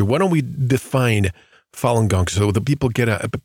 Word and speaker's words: Falun Gong: It's pronounced Falun Gong, also known as Falun Dafa.